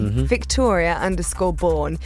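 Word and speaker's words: Victoria Bourne: Directly to me, Victoria_Bourne. And if you're wondering how that's spelled, my surname is spelled B-O-U-R-N-E.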